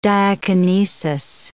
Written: diakinesis

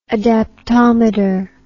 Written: adaptometer

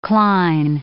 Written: cline

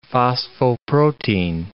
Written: phosphoprotein

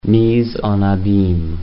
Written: mise en abyme

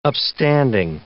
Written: upstanding

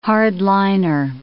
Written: hard-liner

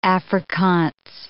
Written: Afrikaans

